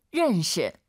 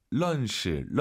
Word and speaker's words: 认识: 认识. – 자, 알 발음 어려우시죠? 런시, 런시. 물론 알아듣잖아요, 선생님. – 是다. – 알 발음 약간 으르르 혀를 뒤로 보내면서 런시.